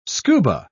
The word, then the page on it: scuba: British English